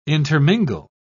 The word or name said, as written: intermingle